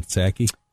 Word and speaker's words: Psaki: So we have Pepe Longstocking, who I believe her name is pronounced. What is her name? Psaki? Jen Psaki? – Jen Psaki.